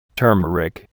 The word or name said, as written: turmeric